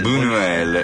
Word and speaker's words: Buñuel: Non so come si chiama, ma si legge gn. – Ah, ho capito, allora lo leggo io. 1900 nasceva Louis Buñuel. L'ho detto bene. – Buñuel.